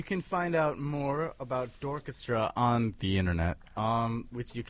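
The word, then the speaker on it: D'orchestra: Again, Dorchestra is spelled D-apostrophe orchestra, but it's pronounced like Dorchestra.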